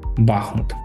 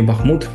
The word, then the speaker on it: Бахмут: Цим прикладом буде місто Бахмут чи Бахмут, як то називає, так як я читав, можна в принципі кожний наголос має право на існування.